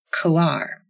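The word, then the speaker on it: Quaoar: Okay, let's move on now and talk about the dwarf planet Coar. And that's Q-U-A-O-A-R is how that is spelled.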